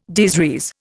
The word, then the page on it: des res: Indian